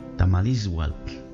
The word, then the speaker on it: Tamalizhuatl: Esas hojas secas se llaman en náhuatl tamasliwatl. Tamasliwatl. De ahí viene la palabra tamali, tamal, tamalizhuatl, tamalizhuatl. Estaba pronunciando mal. Tamalizhuatl.